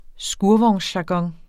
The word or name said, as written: skurvognsjargon